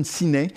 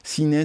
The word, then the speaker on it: Sinay: C'est une interview d'un musicien qui s'appelle Jason Sinai. Sinai, ça s'écrit S-I-N-A-Y, et c'est le guitariste qui joue avec Mike Humble au sein de son groupe les Dirty Knobs.